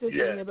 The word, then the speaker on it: Yesenia: I know it's spelled Yesenia, but it's, I'm Puerto Rican, so, like, it's really, the Y's are pronounced with a J. So it's Monique Yassinia, but everybody says Monique Yassinia, and that's my, Yassinia is my middle name.